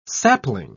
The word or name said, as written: sapling